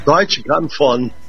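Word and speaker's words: Deutsche Grammophon: Deutsch Grammophon.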